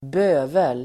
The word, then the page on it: bövel: [²b'ö:vel]